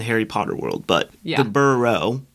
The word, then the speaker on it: Burrow: The Burrows, if you know that from Harry Potter. – Yeah, although Marcus told me I was saying it wrong and I embarrassed him on Instagram. Apparently, it's the Burrow single.